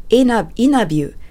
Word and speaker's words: interview: はい、そしてですね、インタビューの発音をアメリカ英語とカナダ英語では他を飛ばして interview と発音します。